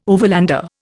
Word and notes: overlander: Indian

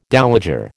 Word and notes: dowager: American English

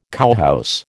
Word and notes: cowhouse: American English

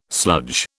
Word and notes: sludge: British English

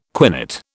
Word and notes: quinate: British English

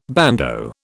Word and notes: bandeau: British English

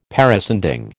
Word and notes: parascending: American English

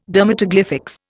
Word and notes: dermatoglyphics: Indian

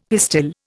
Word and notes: pistil: Indian

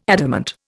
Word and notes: adamant: Indian

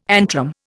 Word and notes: antrum: Indian

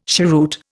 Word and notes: cheroot: Indian

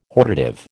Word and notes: hortative: American English

